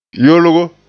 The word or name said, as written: yolgo